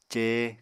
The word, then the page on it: cee: apatani